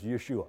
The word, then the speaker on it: Yeshua: But in the Hebrew language, it's Yeshua.